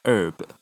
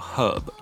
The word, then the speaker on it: herb: 那另外还有一些是像是 h 发不发音的问题哦，比如说有一个字叫 herb 药草，那这个字在美式发音里面它的 h 是不发音的，它念作 herb。然后在英式发音里面，它的 h 反而又变成有发音，它念作 herb。